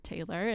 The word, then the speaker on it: Taylr: And so she just kept it Taylr.